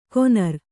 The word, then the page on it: ಕೊನರ್: konar